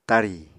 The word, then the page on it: tarì: Apatani